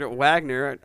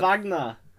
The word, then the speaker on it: Wagner: Gorderdam und Jingang by German composer Richard Wagner. I probably pronounced Richard Wagner. – Thank you.